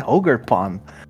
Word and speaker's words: Ogerpon: Pronúncia, né? Ogerpon.